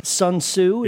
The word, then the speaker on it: Sun Tzu: The Art of War by Sun Tzu. – Yep. – Am I pronouncing that correctly? – Indeed.